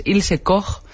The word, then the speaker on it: Ilse Koch: Es Ilse Koch, la zorra de Buchenwald.